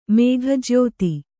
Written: ಮೇಘಜ್ಯೋತಿ

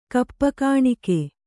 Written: ಕಪ್ಪುಗಾಣಿಕೆ

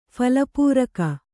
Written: ಫಲಪೂರಕ

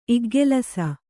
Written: ಇಗ್ಗೆಲಸ

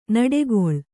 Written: ನುಡಿಗಲ್

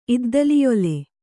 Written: ಇದ್ದಲಿಯೊಲೆ

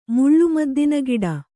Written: ಮುಳ್ಳುಮದ್ದಿನಗಿಡ

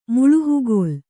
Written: ಮುಳುಹುಗೋಲ್

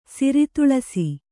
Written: ಸಿರಿತುಳಸಿ

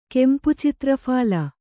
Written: ಕೆಂಪುಚಿತ್ರಫಾಲ

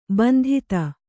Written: ಬಂಧುತೆ

